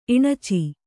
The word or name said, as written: ಇಣಚಿ